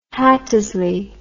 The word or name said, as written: Hattersley